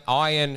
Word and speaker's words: Ayan: It's actually pronounced like iron, like Iron Man, he told me. – Or ironing your shirt. – Ayan Shukla, yeah.